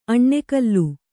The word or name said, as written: ಅನಾಕುಲ